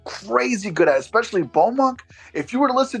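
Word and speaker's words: Baux Monk: Bowmunk. So it's BAUXMONK. That's how you say it, all one word.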